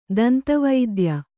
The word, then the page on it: ದಂತವೈದ್ಯೆ: Kannada; danta vaidye